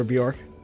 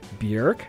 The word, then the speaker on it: Björk: Whenever I played Bjork, remember Bjork? – Mm-hmm. That was Siamese Dream Year. – Siamese Dream Year, 1993? – Yeah. – Maybe. I pronounced her name as Bjork, but apparently it's more like Björk.